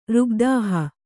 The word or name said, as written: ರುಗ್ದಾಹ